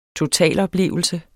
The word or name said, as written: totaloplevelse